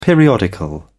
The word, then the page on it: periodical: British English